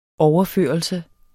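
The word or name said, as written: OVERFØRELSER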